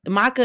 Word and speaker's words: maca: Come on, apparently I was saying it wrong. You checking me. – Oh, what did you say? – I was saying maca. – I mean, I'm pretty sure it could be either one, but I don't know why I say maca. Maca is just my pronunciation. – Okay. Either way, maca, it's M-A-C-A for those of you asking.